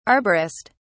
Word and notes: arborist: British English

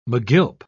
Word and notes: megilp: British English

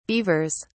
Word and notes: beavers: British English

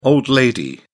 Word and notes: old lady: British English

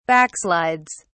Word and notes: backslides: British English

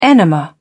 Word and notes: enema: British English

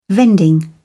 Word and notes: vending: British English

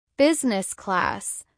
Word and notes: business class: American English

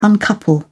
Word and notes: uncouple: British English

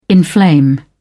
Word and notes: inflame: British English

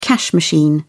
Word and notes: cash machine: British English

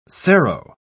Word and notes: serow: British English

